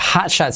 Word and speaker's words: hot shot: La T de hot casi no se pronuncia, de hecho cuesta, ¿vale? Entonces como que se omite.